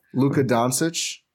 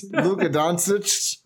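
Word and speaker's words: Luka Dončić: How'd you pronounce that? – Luka Doncic. – Yeah. Someone should tell Lisa that. – Luka Doncic. – Doncic. I love you, Lisa, but that was a little rough.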